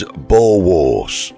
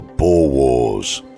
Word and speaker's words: boerewors: And I believe the pronunciation is boerewors or boerewors.